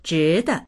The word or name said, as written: zhi2de